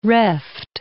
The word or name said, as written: reft